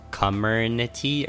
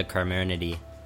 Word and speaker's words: Comirnaty: And apparently the word is spelled Comirnaty. And apparently it's pronounced Comernity or something.